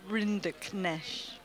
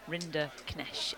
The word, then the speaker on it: Rinderknech: Rinderknech. – Well done. Rinderknech. Have you got it, as well? – Rinderknech.